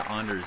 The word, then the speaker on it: Anders: Yeah, Anders is the Swedish.